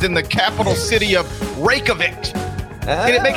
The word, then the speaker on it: Reykjavik: Reykjavik. – You're getting there. – I spell it like rake. Reykjavik.